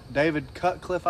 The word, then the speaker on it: David Cutcliffe: David Cutcliffe. Did I say that right? David Cutcliffe, I believe.